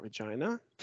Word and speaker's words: Regina: Oh, we know about Regina.